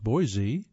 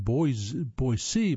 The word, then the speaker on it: Boise: Boise, pronounced Boise by locals, not Boise by locals,